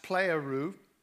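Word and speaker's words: pleroo (πληρόω): This Greek verb to fill is pronounced pleroo, P-L-E-R-O-O.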